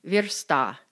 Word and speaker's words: versta: The other headache is stress and pronunciation. Singular, versta. Plural, versti.